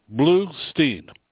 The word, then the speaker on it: Blustein: Is it Bloomstein or Bloomstein? – It's actually Bluestein. – Bluestein.